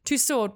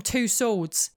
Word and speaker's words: Tussaud: Tussaud, when pronounced correctly, which I guess is Tussaud actually, is a rather soft name that possibly suited her weak husband. I love the fact that the announcement on the tube at Baker Street Station calls out an oft-used mispronunciation. A light here for Madame Two Swords.